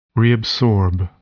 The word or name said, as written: reabsorb